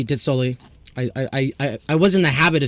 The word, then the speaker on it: Dit-Sully: So you don't pronounce the T of the dit. So Louis Dit-Sully. – Oh, D. Oh, okay. Louis Dit-Sully.